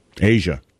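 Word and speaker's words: A'ja: Am I saying that right? – Asia. – Is it Asia? – Like Steely Dan.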